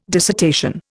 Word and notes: dissertation: Indian

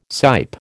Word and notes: sipe: American English

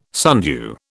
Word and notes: sundew: British English

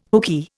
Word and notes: hookey: Indian

